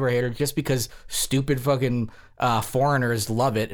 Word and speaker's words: Fucking: It's actually pronounced fucking, like book, like booking with an F.